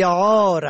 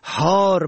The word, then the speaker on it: عار: و در کلمه عار، عین الف ر به معنای ننگ، اگر به هی دو چشم تلفظ شود حار به معنای سگ دیوانه.